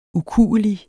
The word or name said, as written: ukuelig